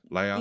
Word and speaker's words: Leia: Leia.